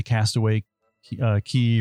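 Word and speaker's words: Castaway Cay: They've been to them, know the managers, stayed at them, have eaten the food, have been to Castaway Key or Castaway K, however you want to pronounce it.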